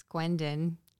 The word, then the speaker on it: Guiendon: It's Guiendon.